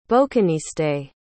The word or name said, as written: bouquiniste